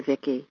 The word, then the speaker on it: Vicki: Everyone seems to have their own spelling for Vicki. V-I-C-K-Y, V-I-K-K-I, V-I-C-K-I-E, but my name is spelled V-I-C-K-I.